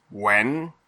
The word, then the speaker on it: Nguyen: It's because it's like some of the Vietnamese names where, like you'll see, it's Wen, but it's spelled like Nguyen, but it's just Wen.